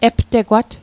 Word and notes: aptegwat: Mi'gmaq

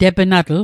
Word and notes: tepnatl: Mi'gmaq

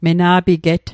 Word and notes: mena'piget: Mi'gmaq

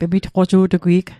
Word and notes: pemitqoju'tugwi'g: Mi'gmaq

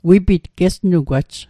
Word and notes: wipit: Mi'gmaq

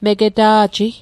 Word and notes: megeta'ji: Mi'gmaq

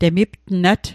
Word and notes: temiptnat: Mi'gmaq

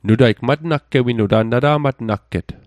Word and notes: nata'matnagget: Mi'gmaq

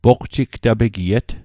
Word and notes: poqjigtapegiet: Mi'gmaq

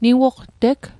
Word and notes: niwoqteg: Mi'gmaq

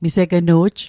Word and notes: misegnu'j: Mi'gmaq

